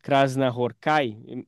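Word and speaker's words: Krasznahorkai: Ele simplesmente traduziu, perdão, o prêmio de literatura, Nobel de Literatura de 2025, agora do ano passado, László Krásna Horkai. Enfim, estou entendendo que essa é a pronúncia. Já vi gente falando com outros acentos, mas essa foi a que mais ouvi.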